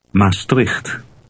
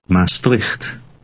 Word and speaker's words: Maastricht: Maastricht. Maastricht.